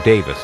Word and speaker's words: Davies: Now, his name is spelled D-A-V-I-E-S, so most Americans tend to pronounce it Davies, even though Davis is the common British pronunciation.